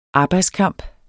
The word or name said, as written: arbejdskamp